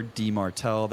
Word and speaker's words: Dimartile: Just search Sean, S-H-A-W-N underscore D-Martell. That's spelled D-I-M-A-R-T-I-L-E.